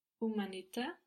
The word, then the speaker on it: umanità: And the H here is not going to be pronounced in Italian. – Umanità.